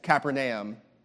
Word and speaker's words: Capernaum: because someone had told me once that it's not pronounced Capernaum, it's pronounced Capernaum. So I asked my friend from Israel who just moved here if it's Capernaum or Capernaum. And he said it's Capernaum, is how you would say it in Hebrew.